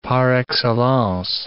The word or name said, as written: par excellence